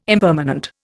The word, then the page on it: impermanent: Indian